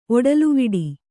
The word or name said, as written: ಒಡಲುವಿಡಿ